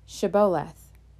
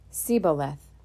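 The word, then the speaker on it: shibboleth: If the men said, No, I'm not, they would tell him to say Sheboleth. If he was from Ephraim, he would say Siboleth, because people from Ephraim cannot pronounce the word correctly.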